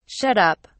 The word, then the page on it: shut up: British English